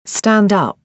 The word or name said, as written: stand up